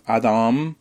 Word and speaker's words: Adam: Escuchémosla un poco en la opereta de Adolphe Adam, para hacerlo bien francés, se escribe Adam, Le Toreador.